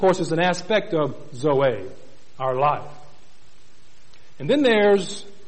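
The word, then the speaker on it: zoe (ζωή): There's another word pronounced zoe, spelled Z-O-E.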